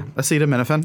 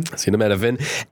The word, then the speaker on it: Acetaminophen: Acetaminophen. – Acetaminophen.